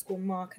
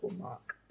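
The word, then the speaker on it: Marc: It's at Charisse _ Mark. And I'll spell it because it's a bit of an arsehole name. Mm-hmm. So it's S-H-E-R-E-E-C-E _M-A-R-C. So at Charisse _ Mark.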